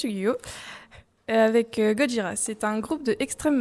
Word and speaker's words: Gojira: Et Gojira, c'est le romaji de Godzilla. Autrement dit, c'est l'écriture de la prononciation japonaise.